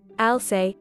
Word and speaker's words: Alsay: Alsay, not pronounced like Princess Elsa in Frozen,